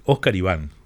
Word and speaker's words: Oscar Iván: Oscar Iván lo digo bien ahora porque ya lo hemos, como nos visitó, me corrigió y dice Oscar Iván. – Todo junto, así.